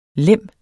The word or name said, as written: LEMMERS